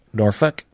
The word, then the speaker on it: Norfolk: Norfolk.